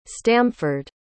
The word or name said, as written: stamford